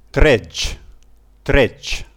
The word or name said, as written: tredg